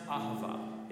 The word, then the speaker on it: Ahavah: It's pronounced Ahavah.